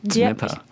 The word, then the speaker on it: Dnepr: Dnepr.